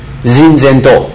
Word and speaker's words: Zinzendorf: 可是最后他们在 1722年的时候呢，他们的 leader 属灵的领袖叫做清前多夫 Zinzendorf，这是德国发音呢 Zinzendorf。